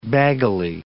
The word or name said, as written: baggily